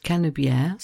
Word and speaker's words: Canebière: And such was its fame that a song was written about it, playing on the fact that Canebière, so the name of the road in French, rhymes with the French word for hemisphere, hémisphère, and making the point that this road was famous all over the world, certainly in both hemispheres.